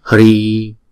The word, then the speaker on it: Hri: Hri, Hri. Chữ Y là 2 nhịp cho nên chúng ta đọc là Hri. Có chữ H trong đó ha, Hri.